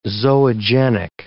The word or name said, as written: zoogenic